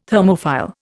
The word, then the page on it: Thermophile: Indian